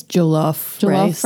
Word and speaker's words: jollof rice: And there's a lot of mentions of, I don't know how to pronounce this, jollof rice? Is that how they say it? I think it's jollof rice.